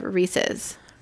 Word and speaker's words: Reese's: It's definitely Reese's, not Reese's. There's no way, there's no world in which that would be pronounced Reese's. – Unless you're Southern, I think. – Southern? – Yeah. I think they call it Reese's. – A lot of people do, and they're wrong.